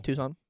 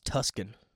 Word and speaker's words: Tucson: T-U-C-S-O-N. Tucson. – Weird way of spelling it. – See, look how you'd be pronounced Tuscan. – Tucson. No, it'd be Tucson because it's T-U-C-S. – Is it? – Yeah. – I thought it was T-S-C-O-N. T-U-S-C-O-N. Okay. – I don't know. Unless I did it backwards. – Oh, no. Tucson makes sense. The C is silent? – I think so.